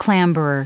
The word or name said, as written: clamberer